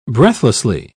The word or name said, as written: breathlessly